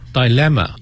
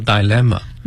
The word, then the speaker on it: dilemma: Dilemma 也可以叫做 Dilemma，两个读音。